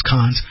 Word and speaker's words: Cannes: They knew him in the film festival Cannes, whatever you want to call it, but it's pronounced Cannes.